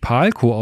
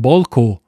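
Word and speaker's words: Pál: Und auch Palko wird anscheinend nicht Palko ausgesprochen, sondern Bolko. Bolko Dardoi.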